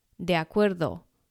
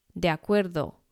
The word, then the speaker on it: de acuerdo: 那当然这个 de 然后紧接着一个母音 a，所以我们要把它连音念作 de acuerdo de acuerdo。